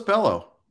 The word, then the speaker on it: Bello: But what did you do with Red Sox rookie Brian Bello, pronounced like Scott Bello? So I did get that one right for once. – I thought it was Bello.